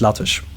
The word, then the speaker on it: LaTeX: Also, ich habe einen Kommilitone, sehr lieber Freund von mir, hat mir das Programm nahegelegt und er hat mir gesagt, da spricht man Latex aus, obwohl dann X steht. Ich selber habe das nur von ihm gehört, sozusagen. Ich glaube, es heißt Latech.